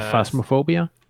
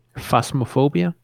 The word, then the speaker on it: Phasmophobia: Yeah, uh, Phasmophobia. – Can you say it again? – Phasmophobia. – Phasmophobia.